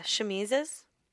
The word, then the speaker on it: chemises: Or shimmies, how do you say that? Yeah, chemises.